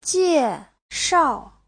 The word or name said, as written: jie4shao4